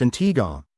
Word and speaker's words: Antigua: Antigua, pronounced Antigua, and Barbuda, Barbuda, is located in the heart of the Caribbean Sea.